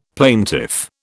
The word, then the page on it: plaintiff: British English